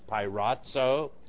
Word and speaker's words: peirazo: Pirazo is the Greek word, P-E-I-R-A-Z-O, pronounced pirazo, like it's A-D-Z-O,